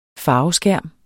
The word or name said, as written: farveskærm